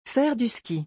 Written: Faire du ski

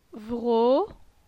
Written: Vro

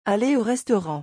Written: aller au restaurant

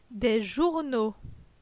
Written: Des journaux

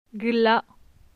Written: Gla

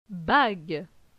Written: Bague

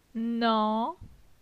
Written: Nan

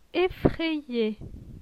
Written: Effraye

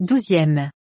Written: 12eme

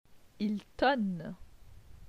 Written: Il tonne

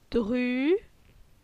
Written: Dru

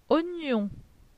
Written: oignon

